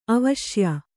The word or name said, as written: ಆವಶ್ಯ